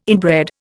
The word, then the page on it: inbred: Indian